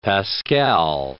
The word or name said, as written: pascal